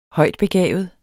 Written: højtbegavet

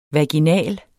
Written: vaginal

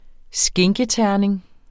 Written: skinketerning

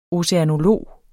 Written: oceanolog

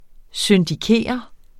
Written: syndikere

